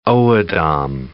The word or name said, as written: O'odham